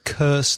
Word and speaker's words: curse: The pronunciation of curse, the same vowel sound as nurse, enfermera. So nurse and curse. I'll put a curse on you. – That's C-U-R-S-E.